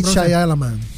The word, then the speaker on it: Shyamalan: ¿Cómo es Shyamalan. – Shyamalan.